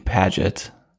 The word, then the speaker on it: Piaget: So you came across this quote the other day by Jean Paget. So I'm going to go with pronouncing P-I-A-G-E-T.